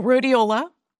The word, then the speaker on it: rhodiola: I've found a supplement called, I can't pronounce the name properly, but rhodiol? – Oh, rhodiola. – Rhodiola.